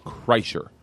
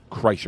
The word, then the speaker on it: Kreischer: She comes up to me afterwards before the show, and she's like, Kreischer. Kreischer. Am I saying that right? Kreischer. I said, yeah.